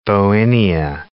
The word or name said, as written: bauhinia